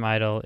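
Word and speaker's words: (G)I-DLE: It's ju- it's usually just Idol. – Idol. Uh, yeah. Interesting. – Yeah. Okay. Is G it a silent, silent G? – Okay. – Well, like it's like lasagna. lasagna. All right, lasagna. Got it. Got it. Okay, so Idol.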